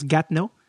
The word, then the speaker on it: Gatineau: Euh, je veux pas parler trop fort parce que j'enregistre présentement tout ça d'une chambre d'hôtel de Gatineau, comme ça se prononce Gatineau.